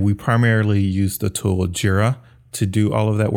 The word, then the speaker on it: Jira: Jira. – Jira, like G. – J-I-R-A. – Okay, I see. – And it's short from Gojira, which is Godzilla.